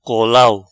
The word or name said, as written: Colau